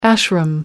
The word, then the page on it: ashram: American English